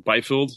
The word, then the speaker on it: Byfield: They have their first-round draft pick with Byfield.